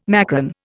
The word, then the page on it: macron: Indian